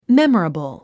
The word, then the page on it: memorable: mémərəbl; メモラブル